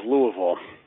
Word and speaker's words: Louisville: Good pronunciation, by the way, of Louisville.